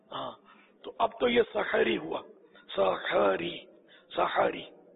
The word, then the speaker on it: سحری: دراصل وہ عربی تلفظ کے حساب سے تو احمد اور سحری یہ ہے اس کا تلفظ. ہا ساکن نہیں ہے, میرا خیال ہے مفتوح ہے, سحر سے ہوا نا سحر, وہ سحر تو جادو کو بولتے ہیں.